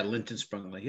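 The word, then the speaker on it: Lindt & Sprüngli: and compliance planner for Lindt Sprungly. Did I say it right? – Yeah, Lindt and Sprungly, yep.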